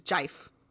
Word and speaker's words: GIF: Everyone knows the actually correct pronunciation is jife.